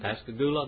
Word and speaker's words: Pascagoula: Pascagoula, Mississippi.